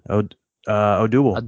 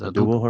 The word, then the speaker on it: Odubel: Abdul? How do you say that? – Oh, one? Yeah. Odubel. – Odubel. Yeah, that's Odubel Herrera.